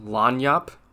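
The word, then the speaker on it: lagniappe: I found out what Lagniap is, and it's actually pronounced Lanyap.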